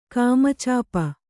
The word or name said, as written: ಕಾಮಚಾಪ